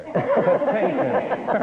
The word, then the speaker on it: Patinkin: Patinkin.